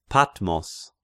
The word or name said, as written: Patmos